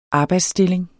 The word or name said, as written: arbejdsstilling